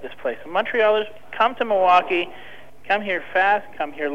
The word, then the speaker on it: Milwaukee: You got that Milwaukee.